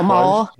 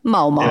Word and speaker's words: Mormor: His grandmother, Mormor, I think you pronounce it, is Glenn Close. – Mormor. Don't do it with a southern accent there. Mormor.